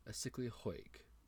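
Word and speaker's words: Aşıklı Höyük: Asikli Hoyuk is how you say it.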